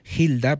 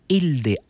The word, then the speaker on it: Hilde: Ella se llamaba Hilda, se escribe así, se pronuncia Hilda, pero es Hilde, H-I-L-D-E, H-I-L-D-E, Hilde. Bach, B-A-C-K, Hilde Bach, o sea, H-I-L-D-E, B-A-C-K, Hilde Bach.